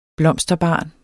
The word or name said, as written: blomsterbarn